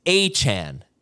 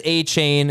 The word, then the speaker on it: Achane: It's Achane. That's Achane. – So everyone says Achane, including the Dolphins media guide. Achane is how he wants it pronounced, according to Adam Schefter.